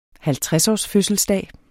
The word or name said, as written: 50-årsfødselsdag